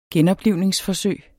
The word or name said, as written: genoplivningsforsøg